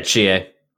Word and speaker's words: Chie: This week's matchup is going to be between Chi, or how do you say her name? Is it Chie? – Yeah, Chie. – Chie, okay, yes.